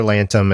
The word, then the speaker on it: Lantom: How do you pronounce his last name? Does anybody know? Does anybody remember? It's spelled L-A-N-T-O-M. So I think it's Lantham. Yeah. So he asked Father Lantham at the church, and he goes there.